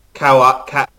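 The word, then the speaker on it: Caol Ila: The other one is Kawalila.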